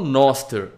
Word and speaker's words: Nostr: É, eu falo Noster, porque, porque os, os gringos falam Noster, né? Mas se escreve N-O-S-T-R. Não tem o E, mas o pessoal fala Noster.